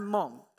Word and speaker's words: Hmong: So ethnically, I'm Hmong. That's Hmong with a silent H on the front end.